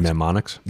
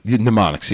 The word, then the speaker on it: mnemonics: Mnemonics? – Mnemonics, yes.